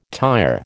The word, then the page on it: tyre: American English